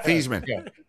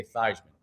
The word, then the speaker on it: Theismann: Yeah, Theismann. Change his name to rhyme with Heisman. – Lindsey Nelson used to say Thiesman. Everybody else used to say Thiesman.